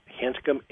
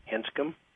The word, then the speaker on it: Hanscom: Hanscom, H-A-N as in Nancy, S, C as in Charlie, O, M as in Mary, Hanscom.